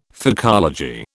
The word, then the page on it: phycology: British English